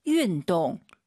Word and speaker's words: 运动: 자, 운동 발음할 때 여러분 윈동 입을 가로로 벌려서 윈동 윈동 다 알아들어요. 예쁜 발음이 아니에요. 다시 들어보세요. – 运动。 – 윈동 약간 입술을 동그랗게 말아보세요. 윈동 그게 더 예쁜 발음이에요.